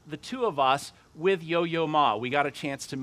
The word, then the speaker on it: Yo-Yo Ma: If you don't know who Yo-Yo Ma is, yes, I am saying that correctly.